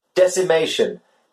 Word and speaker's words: decimation: Decimation, destruction, killing of large numbers of something. – Yes, it's D-E-C-I-M-A-T-I-O-N. Decimation. The C is pronounced with a S sound.